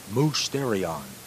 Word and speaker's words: musterion: Now, that's very interesting, the mystery religions, because the Greek word that is involved here is a word, it's the word musterion. I'll spell it for you in English. M-U-S-T-E-R-I-O-N. That's the Greek word, musterion.